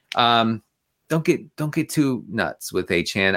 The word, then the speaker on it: Achane: And it's Achane. – I'm gonna invent a silent E.